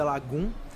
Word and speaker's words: Lagom: Primeiro, que é, é, Lagoon eu acho que é uma cidade na Suécia e também é um estilo de vida da Suécia se escreve Lagom, mas se pronuncia Lagum.